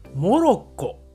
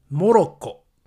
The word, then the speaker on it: モロッコ: 日本語で発音すると、モロッコと言います。モロッコ。